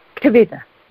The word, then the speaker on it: Kavita: Kavita. K-A-V-I-T-A. – Spell it. – K-A-V-I-T-A. – I thought so, K-A-V-I-T-A.